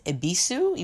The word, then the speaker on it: Ebisu: It's pronounced Ebisu, even though there's a Y in front of it.